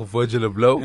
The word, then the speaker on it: Virgil Abloh: Oh, Virgil Abloh? – Yeah, absolutely.